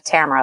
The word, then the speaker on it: Tamara: My name is, you said it correctly, Tamara Lackey. A lot of people say Tamara or Tamara, so you said it right.